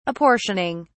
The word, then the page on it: apportioning: British English